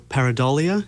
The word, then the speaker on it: pareidolia: So that's a good case for pareidolia. Is that how it's said? – Yeah, because that's exactly what it is.